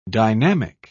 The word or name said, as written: dynamic